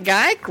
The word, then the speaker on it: Geich: But y'all, that Nathan, the better half of Pat Geich, rhymes with Dyke.